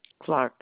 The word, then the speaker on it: Clarke: Clark, C-L-A-R-K-E. – And can you spell your first name for me? – R-O-W-E-N-A.